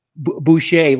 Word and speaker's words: Boucher: Boucher, like Bobby Boucher from The Waterboy.